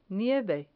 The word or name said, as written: nieve